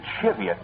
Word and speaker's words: Cheviot: I'll never forget a small rampart that I once almost thought was in Cheviot, Ohio, whereas the natives pronounce it Cheviot by some odd twist of the local larynx.